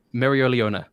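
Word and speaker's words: Mereoleona: All right, I was waiting to talk about Black Clover because I want to hear Rustage pronounce Mario Leona. Please go for it, Rust. – Mario Leona. Bang. – Oh, nice. First try!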